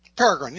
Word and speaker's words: Peregrine: The I can never pronounce this name, and you're going to have to help me, and we talked about it in the Bird episode. Is it the Peregrine? – Peregrine, yes.